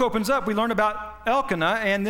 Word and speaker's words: Elkanah: but it's Elkanah, okay, according to the accents that's in that list in the front of your Bible.